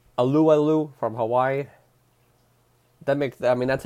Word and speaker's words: Alualu: Our next one is Tyson Alualu. How to pronounce the name according to Wikipedia? Alualu. That's how you pronounce his name.